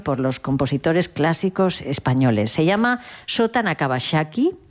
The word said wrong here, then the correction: Nakabayashi: No, Sota Nakabayashi. Ahora lo he dicho bien.